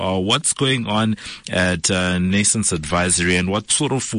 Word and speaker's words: Nascence: Am I pronouncing Nascence Advisory correctly? – Very well.